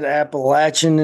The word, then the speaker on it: Appalachian: By the way, when I said Appalachian Intelligence when we recorded with the Cryptids of the Corn, Justin, somebody left a comment on YouTube that said, say it right. – He did say it right. Whoever that person on YouTube is, he is saying it the right way.